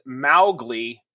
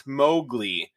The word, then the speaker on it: Mowgli: According to his daughter, Elsie Kipling, Mowgli is pronounced Mowgli, not Mowgli. It is said that she never forgave Disney for this mistake.